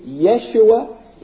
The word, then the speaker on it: Yeshua: It is pronounced in Hebrew, Yeshua.